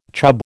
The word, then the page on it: chub: American English